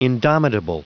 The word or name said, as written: indomitable